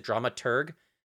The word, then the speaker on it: dramaturg: Is it dramaturg? Drama, T-U-R-G? Is that how you? – Yep.